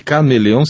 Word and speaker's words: K-Meleon: E esse Chameleon se escreve K-Meleon, com N final, N de navio no fim. Mas eu falei no Antônio Tessitore porque ele também dá uma dica de livros.